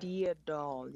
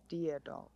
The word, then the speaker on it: Deadol: Deadol, yeah. – Deadol. – Yes, Deadol, yes.